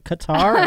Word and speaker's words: Qatar: Am I saying that properly, you think? – Qatar? – I think it's Qatar. Qatar. – Okay. Qatar. It's like guitar, but Qatar.